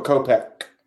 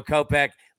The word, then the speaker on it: Kopech: How do you pronounce my man's name pitching for the White Sox there? – Michael Kopech. – There we go. Kopech. Look, we always say he sounds like an old New York City detective there.